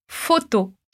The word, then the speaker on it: photo: Então, se no português a gente diz foto, o primeiro com som de O e o segundo com som de O, no francês, a gente só vai ter o som de O. Então, repete comigo. Photo.